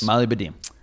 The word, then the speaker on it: Molybdenum: M-O-L-Y-B-D-E-N-U-M. Just so that everyone can go with their own pronunciation. – Yes. – Molybdenum.